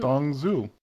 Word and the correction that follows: Dong Zhuo: I would like to point out to you, that you did not pronounce that name correctly. – Tong Zhuo. – I was not trying to. – Did I pronounce it right? – That was closer. – Dong Zhao. – Thank you.